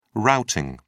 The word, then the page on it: routing: British English